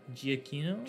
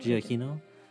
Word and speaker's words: Giacchino: Giacchino. Giacchino.